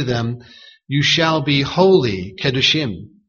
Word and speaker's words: Kedoshim: Here the reference is Elohim Kedoshim. Kedoshim. Leviticus 19 and verse 2. Kedoshim is spelled K-E-D-O-S-H-I-M.